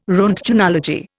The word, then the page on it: roentgenology: Indian